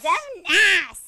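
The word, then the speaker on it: Xemnasss: But it's X-E-M-N-A-S-S-S. – I thought that was like Zemnass, like an ass. – Zemnass, yeah! Kinda, sorta, I guess.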